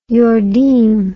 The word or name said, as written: yordim